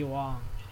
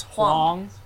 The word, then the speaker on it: Huang: It's Huang, but, like, yeah, he likes Eddie to pronounce Huang. It Wong just for white people's sake and not have it butchered.